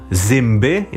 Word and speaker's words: zymby: Albo zęby to są zymby i to się pisze jako z, y, m, b, y.